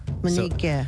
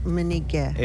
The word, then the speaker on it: mineekeh: And this is spelled M-I-N-E-E-K-E-H, but that K sounds like a G. – Yeah, it sounds kind of like a G, but it's more so like a really soft K. So if I say Miniga. – Miniga. – Yeah, so it's not so much like a hard G. It's kind of like a mix.